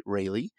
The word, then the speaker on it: Raley: You spell his name R-A-L-E-Y, but he pronounces it Raley.